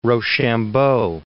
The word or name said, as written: Rochambeau